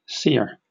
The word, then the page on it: sear: British English